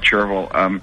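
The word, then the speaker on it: Chervil: I've heard it pronounced a few ways, but I call it Cherville.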